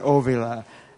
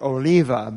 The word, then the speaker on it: Ovila: The accent is on Ovila. we, we argued about the, uh, we didn't argue, we discussed about the label because they wanted to dot the I and everyone pronounces it Oliva, but so it's oh, Ovila.